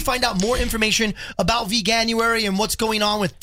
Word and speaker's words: Veganuary: I always say it wrong. – Veganuary.